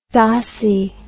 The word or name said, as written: dossy